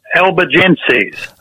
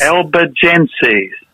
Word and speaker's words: Albigenses: Have I have I said that word right? – Albanese. Albigenses. – Ah, how do you say it? Albigenses. – Albigenses.